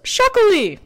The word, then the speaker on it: Txakoli: It's Shaquely or Shaquelina, which is spelled, maddeningly enough, TXAKOLI or TXAKOLINA, if you're saying Shaquelina.